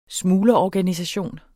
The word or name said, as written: smuglerorganisation